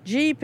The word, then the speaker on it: Gepp: How do you pronounce G-E-P-P? – This, too, is close to where I grew up. This is Jeep. – Oh, really? – Yeah. – Uh-huh. Yeah. – Jeep. – Jeep. Yeah. Not Gep, nope.